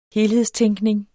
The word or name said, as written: helhedstænkning